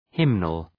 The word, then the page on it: hymnal: {‘hımnəl}